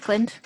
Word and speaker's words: Auckland: How do you pronounce Auckland? – Auckland.